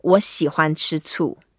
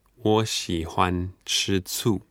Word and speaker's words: 我喜欢吃醋: So, 我喜欢吃醋. – 我喜欢吃醋. – That's right. 我 is I. 喜欢, like. – Like. 我喜欢吃醋.